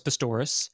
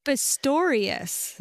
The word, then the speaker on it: Pistorius: Okay, so this um, this came from Boris Pastoris. Great name. – Pistorius. – Yeah. Well, I like to say Pastoris No, it rhymes. I know. No, be polite. – Dave's listening. Pronounce and he's his like, name. You got it wrong.